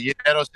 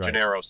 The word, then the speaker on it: Gineros: Gineros is the Greek way, and Gineros is the American.